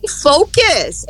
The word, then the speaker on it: focus: Mas em focus, ela não é A, não. Porque senão vai ficar palavrão mesmo. Então, ela é fo.